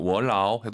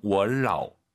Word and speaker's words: 我老: 라오는 우리 왕 선생님처럼 올라가면 안 되죠. 월라오 해도 알아듣지만 틀린 발음이에요. 뒤에가 일성이니까 반삼성 월라오.